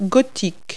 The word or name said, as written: gothic